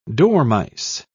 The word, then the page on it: dormice: British English